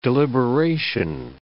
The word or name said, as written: deliberation